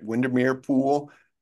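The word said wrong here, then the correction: Widmerpool: and Widmer Pool.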